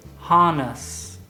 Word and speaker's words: harness: Arnés is harness. Harness, with the stress on the first syllable.